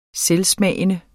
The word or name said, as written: selvsmagende